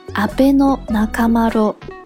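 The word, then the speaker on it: 阿倍仲麻呂: 比如阿倍仲麻吕，他是呃中国历史上最有名的日本留学生，大家学过高中历史应该都知道，他的名字就叫做。阿贝诺纳卡马罗，最后一个吕字读作罗。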